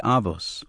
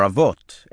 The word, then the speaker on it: Avot: Many of the quotes Reb Saunders had used had been from Pirkei Avos, or Avot, as my father had taught me to pronounce it, with a Sephardic, rather than the Ashkenazic rendering of the Hebrew letter Toph.